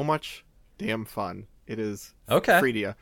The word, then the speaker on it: Freedia: F-R-E-E-D-I-A.